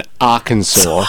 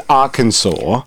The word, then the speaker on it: Arkansas: Because Arkansas should be spelt as in Arcan, S-O-R-E. – S-O-R-E or S-A-W, sore. – Yeah, that would, S-O-R-E would make more sense, but sure, if it has to be sore, at least make it sore.